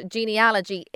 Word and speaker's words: genealogy: And I'm glad to hear you pronounce it genealogy, because a lot of people I hear pronounce it genealogy, but I always joke and say it's analogy, not analogy.